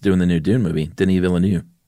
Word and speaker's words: Denis Villeneuve: His name is Denis Villeneuve.